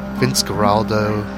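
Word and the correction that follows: Vince Guaraldi: Sorry about that. Vince Giraldi did Choro with Bolasetti.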